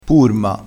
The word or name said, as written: purma